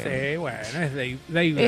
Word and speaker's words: Dave: David Grohl. – Dave. – Dave. Ah, entonces lo dije bien. – Se dice Dave.